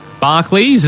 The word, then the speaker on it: Barclays: Barclays, is that how you say it? – Yeah.